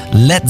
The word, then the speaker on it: Led: Led Zeppelin. Das A in Led ließ er einfach weg, damit es nicht wie Blei ausgesprochen wird.